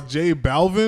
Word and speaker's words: J Balvin: So J Balvin, I don't know if I'm pronouncing that right. – Yes.